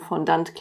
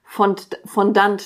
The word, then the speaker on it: Fondant: Und habe sofort Korrekturen meiner Aussprache bekommen mit, das heißt Fondant. Es ist ein Fondant. – Ach. – Aber es ist halt einfach in Deutschland kein Fondant, so wie es kein Croissant ist, sondern Croissant. Weißt du, wie ich meine? Also es ist halt eine eingedeutschte Aussprache, aber sofort musste mich halb Instagram belehren, wie ich Fondant richtig sage.